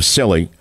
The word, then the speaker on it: Scilly: Again, bringing you evidence from the islands of, oddly enough, it's called Islands of Scilly, but it's pronounced S-C-I-L-L-Y. We're not talking about Sicily.